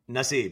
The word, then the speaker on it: Nassib: Nassib, yes.